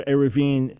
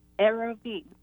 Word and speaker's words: Eruvin: Yeah, Eruv is singular, Eruvin is plural. Yeah. – Eruvin, okay.